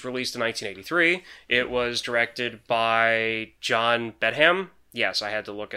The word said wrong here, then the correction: Badham: John Badham. Bedham? Badham? Bedham? Okay, something. One of those pronunciations. – Badham. Badham. – I like that one.